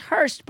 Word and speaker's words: Horst: Wait, I just want to begin by saying, if you guys go to look for Danae's book in the store, her last name is pronounced Hurst, but it's spelled H-O-R-S-T.